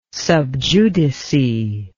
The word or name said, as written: sub judice